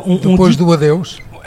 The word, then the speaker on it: Depois do Adeus: La chanson au moment de l'Eurovision, hein, qui est diffusée à Depois, alors je ne sais pas prononcer le Portugal, et après les adieux de Paulo de Carvalho. – Ouais, Paulo de Carvalho. Depois do adeus.